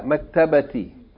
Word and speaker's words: مكتبة: But it doesn't say fil maktabi, it says fil maktabati. Make sure you pronounce the ta, maktabati.